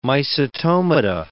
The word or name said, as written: mycetomata